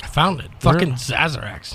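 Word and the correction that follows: Sazerac: S-A-Z-E-R. – Oh, Sazarax.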